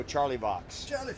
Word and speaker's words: Charlevoix: Matt S., Charlevoix, Michigan. – Okay. – I pronounced that very French, didn't I? You'd go Charlie Box.